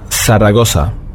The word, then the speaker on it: Zaragoza: Zaragoza, das wird ausgesprochen wie ein TH auf dem spanischen Festland. Hier allerdings haben wir nur ein scharfes S, Zaragoza.